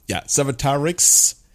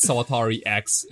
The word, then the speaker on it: Sawatarix: That's not the most audible, Sawatarix. Everybody's going to spell it with CKS in the end. – Right. It's not Sawatari X, it's Sawatarix.